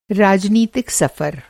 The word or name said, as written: सफ़र